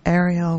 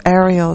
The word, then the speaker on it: Ariel: So Ariel is pronounced the same as oven or grill in Hebrew.